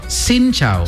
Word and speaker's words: Xin chào: Him chào. – Xin chào. – Xin chào. – Xin chào is hello.